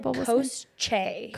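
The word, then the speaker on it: Koschei: And Koshi or Kochi. Oh, Coach, how would you say that? – It said Kosche. – Kosche. Okay.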